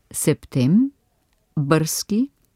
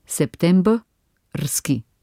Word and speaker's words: septembrski: Lahko jih delimo tudi septem, brski, okto, brski, novem, brski, decem, brski. Nikakor pa ne september, rski, oktober, rski, november, rski, december, rski. Kar je besedni del rski neizgovorljiv soglasniški sklop, kaj ne?